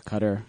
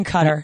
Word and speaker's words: Qatar: Qatar, Qatar. – They're saying Qatar. So I feel like we need to call it Qatar now.